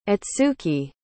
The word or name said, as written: itsuki